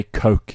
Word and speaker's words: Koch: They pronounce their surname. I mean, we say Koch or Koch here, but they say Coke in America.